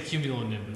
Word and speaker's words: cumulonimbus: I'll say cumulonimbus.